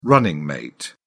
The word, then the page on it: running mate: British English